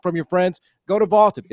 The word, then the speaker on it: Vlted: And by using the Vaulted Challenge feature, you can prove you're smarter than your friends. So go download the Vaulted app and give it a try for free. Vaulted is spelled V-L-T-E-D, and it's the app to challenge your friends, store your predictions, and join daily cash prize pools without any entry fee.